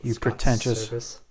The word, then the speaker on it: Servais: It's Scott Servais. – Well, it's spelled S-E-R-V-A-I-S. – Yeah, he's a major league catcher. – Okay. – For the longest time. Servais. – Servais is what I said.